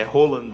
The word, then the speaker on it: Haaland: Se o Haaland viesse, é Roland, né, como se fala, viesse, seria possível o Gabriel Jesus jogando pelo lado também?